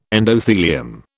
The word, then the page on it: endothelium: British English